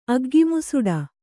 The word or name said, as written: ಅಗ್ಗಿಮುಸುಡ